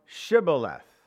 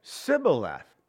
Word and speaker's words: Shibboleth: Then said they unto him, say now, Shibboleth, this word Shibboleth, and he said, Sibboleth. This is interesting, for he could not frame to pronounce it right.